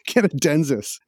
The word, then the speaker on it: Canadensys: Bolton based Canadensi. No, that can't be right. Canad- Canadensys. Am I pronouncing that right or wrong? – Yeah, that sounds about right. It's definitely not Italian. So I think the second time was better.